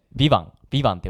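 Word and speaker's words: VIVANT: あれとか見てない、あの日曜劇場かのあのビバンとだっけ。 – もう全然わからんわ。 – ビバンって読むらしいです。今ちょっとあの横からマネージャーのおばさんが言って、ビバンって読むらしいんですけど、俺も全然見てなくて。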